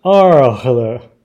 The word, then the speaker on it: Arles: 那呃，上一集我们离开亚维农之后呢，就来到这个呃，就梵谷曾经在这边住过好呃好呃十五个月的这个 Arles。 – 是我们刚我们有查这个怎么读，法文好像叫 Arles。 – Yeah. – 对，所以我之前读错。